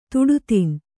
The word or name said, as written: ತುಡುತಿನ್